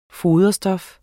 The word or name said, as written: foderstof